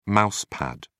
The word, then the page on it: mouse pad: British English